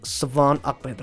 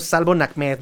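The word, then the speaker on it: Salvon Ahmed: Savon Ahmed, Savon Ahmed. Recuerden, así se pronuncia. No es Salvo Ahmed, no, no, no, es Savon Ahmed. Así lo pronuncian, amigos.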